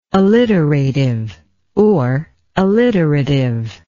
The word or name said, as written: alliterativeness